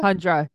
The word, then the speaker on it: Hundra: Am I saying that right? Hundra? – Hundra. Yeah.